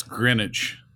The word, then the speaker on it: Greenwich: A resident of the wealthy community of Greenwich, Connecticut, I guess. Martha had been bludgeoned to death with a golf club. Talk about a... – It's Greenwich.